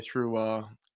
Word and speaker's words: ThROH: Yeah, I have a podcast with my friend Matt Feuerstein covering Ring of Honor history called Through the Years. It's spelled THROH for through, if you want to look it up.